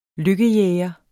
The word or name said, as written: lykkejæger